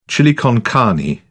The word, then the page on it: chili con carne: British English